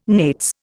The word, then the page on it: nates: Indian